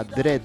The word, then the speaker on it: Dread: ¿Cómo se pronuncia? – Dread. – ¿Cómo? – Dread. – Aquí dice Dread. – Pero se pronuncia Dread.